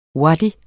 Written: wadi

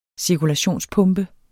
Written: cirkulationspumpe

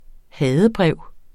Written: hadebrev

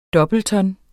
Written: doubleton

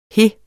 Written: he

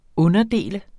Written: underdel

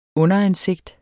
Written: underansigt

